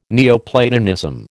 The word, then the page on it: Neoplatonism: American English